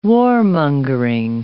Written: warmongering

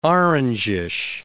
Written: orangish

